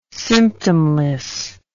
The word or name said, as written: symptomless